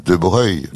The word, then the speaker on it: Broglie: les gens de famille disent De Breuil.